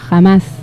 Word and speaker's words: Hamás: Se pronuncia Hamás y es una organización islamista palestina cuyo objetivo es la instauración de un Estado Islámico en la región histórica de Palestina.